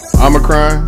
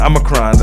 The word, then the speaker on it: Omicron: Omicron. – Thank you. That's one of them words, man. Omicron.